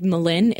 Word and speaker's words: Mlynn: And then TFIL by, I think you say it like Malin, it's MLYNN.